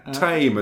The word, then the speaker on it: Thame: Tame is a place name I always find amusing. – That's right. It's spelt Thame, right? – It's spelt Thame, but it's...